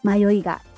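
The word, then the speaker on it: 迷い家: で、さてさて、この迷いがっていう、音なんですけど、漢字にすると、迷うですね。米辺書いて信用入れる迷う。で、その迷い、家です。家。なんで、これで迷いがって読んでるみたいです。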